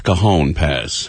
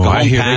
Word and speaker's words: Cajon: How do you pronounce C-A-J-O-N? – I think it's Cajon Pass. – Oh, yeah, Cajon Pass.